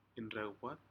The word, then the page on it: indruwpat: Aneityum